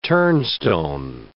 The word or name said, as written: turnstone